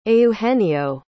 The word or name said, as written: eugenio